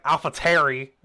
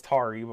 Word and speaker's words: AlphaTauri: David Croft got me fucked up on that pronunciation. He's like, AlphaTauri. I was like, girl, it's Tauri, but whatever.